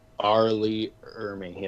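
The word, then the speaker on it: R. Lee Ermey: Is that his name, right? Arlie Ermey? Or am I saying it wrong? – Arlie Ermey, yeah.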